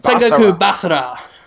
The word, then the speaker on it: Sengoku Basara: Sengoku Basara? Is that how you say it? – Sengoku Basara.